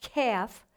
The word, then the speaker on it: Kaph: In the Hebrew, the letter K-A-P-H, which is pronounced calf, represents the number 20, and it symbolizes redemption.